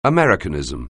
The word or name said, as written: Americanism